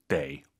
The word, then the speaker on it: 得: 59페이지 데이 어떨 때는 더. 사실은 발음이 또 있어요. 근데 오늘은 두 개만 해보시죠. 아무리 강조해도 지나치지 않습니다. 수러 즉 동사나 형용사 앞에 있으면 더가 아니라 데이입니다.